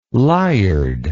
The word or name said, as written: lyard